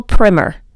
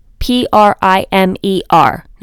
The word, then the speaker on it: primer: Um, oh, the Gospel Primer, P R I M E R. Now I want to say it's primer, but somebody told told me it's pronounced primer, but I say the Gospel Primer.